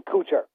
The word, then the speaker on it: Kuchar: I've heard his name pronounced Kuchar and Kuchar.